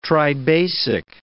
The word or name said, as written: tribasic